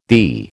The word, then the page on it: thee: American English